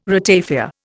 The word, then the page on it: ratafia: Indian